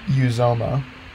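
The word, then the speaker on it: Uzomah: First player, CJ Uzuma. Just why not? – Okay, so first off, it's pronounced Uzoma.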